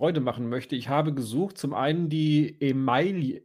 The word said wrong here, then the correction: Emaille: Emaille spricht man das so aus? Ema, Emaille? – Emaille. Emaille.